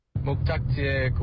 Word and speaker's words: 목적지: The jok sound is a bit tricky sometimes. The o vowel sound. Not an o, not an a, but an o. 목적지에 거의 도착했어요. 목 has the o, then jok has the a. And yeah, that can be tricky to differentiate for non-Korean native speakers.